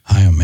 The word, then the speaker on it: Haim: I definitely should have known the Haim girls. – Yeah, I didn't. Is that pronounced that correctly? – Haim, yeah. – Haim girls.